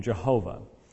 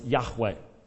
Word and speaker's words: Yahweh: That's not how the ancient Hebrews would have pronounced it. The vast majority of scholars would accept that in all probability, the pronunciation of the divine name, the tetragrammaton, Yod, He, Wow, He, was Yahweh.